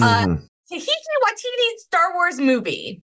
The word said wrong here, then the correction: Taika Waititi: Taika Waititi. – What did I say? – Tika. You said it like it was a tiki. Tiki Waititi. It's definitely Taika.